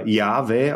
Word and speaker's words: Yahweh: Dann kommt nämlich sein Name, der wird als Tetragramm bezeichnet, vier Buchstaben, J-H-W-H, wird wahrscheinlich so etwa Yahweh ausgesprochen.